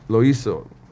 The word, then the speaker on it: Loyiso: And when you're doing that and you're reading up the paper at the same time, like even when I was introducing Loiso, right? I said it right. – Yeah. I said, now you did.